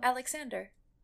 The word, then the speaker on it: Alexander: Oh, Alexander.